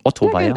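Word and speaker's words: Ottersweier: Habe ich das richtig ausgesprochen? Otteweier? – Ja, Otteweier.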